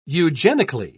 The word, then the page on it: eugenically: British English